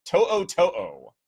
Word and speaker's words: To'o: To'o is the pronunciation there.